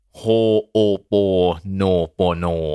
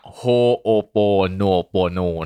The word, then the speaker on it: Ho'oponopono: โฮโอโปโนโปโนลองอีกทีหนึ่งนะฮะมันออกเสียงว่าโฮโอโปโนโปโน